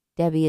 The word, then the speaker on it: Debby: Debbie is spelled D-E-B-B-Y.